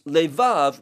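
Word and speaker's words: levav (לבב): The second one is levav, which is spelled lamed vet vet.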